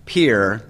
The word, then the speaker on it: Pierre: Now, real quick, just for a little background information here, Pierre is the state capital. It's P-I-E-R-R-E, and we pronounce it Pierre, not Pierre.